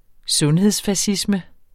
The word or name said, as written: sundhedsfascisme